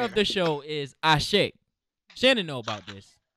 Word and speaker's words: Ase: It's spelled A-S-E, but it's pronounced Ashe.